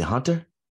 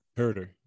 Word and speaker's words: Huerter: Uh, is it Hunter? How you say it? – Huerter.